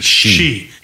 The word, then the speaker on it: Xi: Xi.